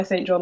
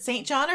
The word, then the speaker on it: St. John: As a first name, St. John is usually pronounced St. John, but as a last name, it can be pronounced as St. John.